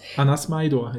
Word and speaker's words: Smajdor: Anna Smaidor heißt die Autorin aus Norwegen. – Ach so, ja. hast du direkt schön ausgesprochen, ne? – Ja.